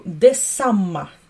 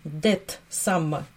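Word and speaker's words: det samma: Де самма. Э, внимание, Т, дет, самма, дет. Не читаем Десамма. Десамма.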